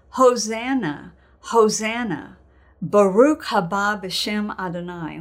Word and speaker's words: Hosanna: And so they're saying, "Save us, O King, Hosanna," which we pronounce Hosanna, Hosanna.